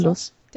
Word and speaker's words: Daedalus: Project Daedalus, oder wie spricht man das? Daedalus.